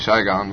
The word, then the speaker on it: Saigon: I said Saigon because most of the S's in Vietnamese are pronounced as SH, and I forget.